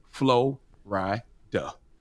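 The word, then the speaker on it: Florida: the place that is one word, two or three syllables, depending on how you pronounce it, Flo-ri-da.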